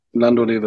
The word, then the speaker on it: Landaulet: Und es geht um einen Daimler DS 420 Landaulet oder Landolette wird es geschrieben. – Land Landaulet heißt es, glaube ich, ja. Lando Landaulet wird es ausgesprochen, genau.